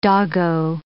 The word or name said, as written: doggo